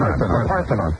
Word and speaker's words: Parthenon: Parthenon.